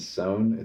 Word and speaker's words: Sohn: There's a artist named Sohn. It's spelled like John, but with an S instead of a J mm-hmm.